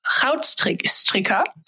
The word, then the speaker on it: Goudstikker: der Jacques Rautstricker, so spricht man ihn wohl aus. Also es wird mit G geschrieben.